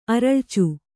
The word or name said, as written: ಅರಳಿಚು